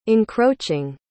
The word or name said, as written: encroaching